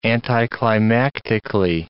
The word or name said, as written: anticlimactically